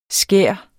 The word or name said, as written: skær